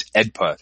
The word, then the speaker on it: AEDPA: Now, this law is always is referred to as EDPA. That's just how how it's uh, how lawyers uh, pronounce this act, this this law.